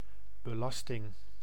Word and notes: belasting: [bə.lɑs.tɪŋ]

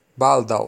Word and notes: soon: /suːn/; American English; [suːn]

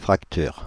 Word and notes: fracture: France; [yn fʁak.tyʁ]

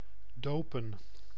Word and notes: dopen: [do.pəⁿ]

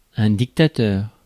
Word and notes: dictateur: [dik.ta.tœʁ]; France

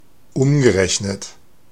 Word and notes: equivalent: American English; /iːˈkwɪvælənt/

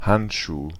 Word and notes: Handschuh: /ˈhantʃuː/

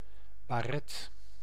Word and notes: bonnet: /bɔ.nɛ/; [bɔ.nɛ]

